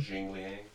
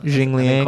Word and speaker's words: Jingliang: Yeah, Li Jingliang versus is that how you pronounce that? That's such a weird name. – Jingliang. – Yeah. – Jingliang.